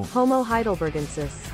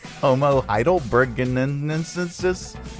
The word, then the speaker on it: Homo heidelbergensis: Uh, homo homo homo heidelbergensis. – Hi, Homo heidelbergensis? – Homo heidelbergensis.